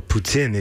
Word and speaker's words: poutine: Well, poutine, if you really want to say it right and not get everybody bent out of shape. But poutine is fine.